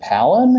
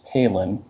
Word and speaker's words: Palin: So is her name pronounced, is it Palin? And they're like, no, it's Palin, giving me a dirty look so that I didn't know how to pronounce their governor's name.